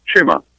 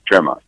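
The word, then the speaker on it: Txema: Pronunciation is Chima.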